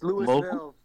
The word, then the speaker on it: Louisville: It's Louisville. – Is it Louisville? – Yeah, it's pronounced Louisville. Trust me, the fucking locals here bite your fucking head off if you call it Louisville.